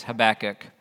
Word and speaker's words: Habakkuk: It's Habakkuk, whatever.